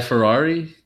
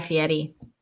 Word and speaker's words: Fieri: Anyway, it's Guy Ferrari? – Actually, yes, that is it. But apparently his name is pronounced Guy Fieri.